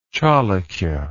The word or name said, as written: chalukyan